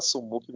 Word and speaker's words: SumUp: A gente abre abra, brasileiro o nome, né, e fala sumup mesmo, mas se não me engano, na pronúncia original, na americana, sei lá, é Sumap, apesar que essa empresa, ela, a sede dela é na Alemanha, né, acho que é a matriz dela.